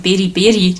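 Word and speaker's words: Beriberi: Das heißt nicht Badi Badi, sondern Beri Beri, die Krankheit.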